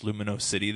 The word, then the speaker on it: Lumino City: Oh, Luminosity. I'm sorry. Yeah. – I think it's pronounced Lumino City, though, because it's like...